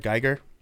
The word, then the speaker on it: Giger: Do you pronounce it Giger or Geiger? – Who? – Are you familiar? He's an artist. I'll send you a link. Whatever. – Swiss painter. – What? – Swiss painter H.R. Geiger.